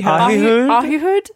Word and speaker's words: Ahihud: "Of the tribe of the children of Asher, a prince, Ahud. How would you say this? Ahihud. – Ahihud. – Ahihud. Okay.